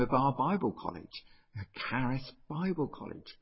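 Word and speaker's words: Charis (χάρις): The Greeks would pronounce it. Charis.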